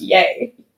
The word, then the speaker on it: Ye: And yeah, even like, and when he always referred to like it before, like it was like ye, and now it's pronounced yay.